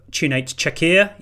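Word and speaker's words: Cüneyt Çakır: Kunyet Çakır, is he still on it, or is he retired? – I will accept the pronunciation of Çunate Çakır.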